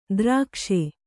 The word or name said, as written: ದ್ರಾಕ್ಷೆ